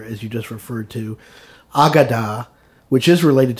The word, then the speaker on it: Haggadah: Haggadah.